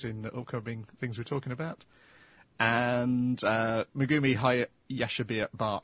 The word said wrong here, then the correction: Hayashibara: a name always looks easy to pronounce written down than me actually trying to say it. Hayashibara.